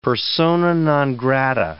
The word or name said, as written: persona non grata